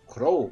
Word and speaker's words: Crow: Né, o, é, o, o, Tony falou do Cru, né? Crow. – Ah, é verdade. – Crow, é. Dessa época também.